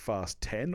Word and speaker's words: Fast X: Fast X. Pronounced Fast 10,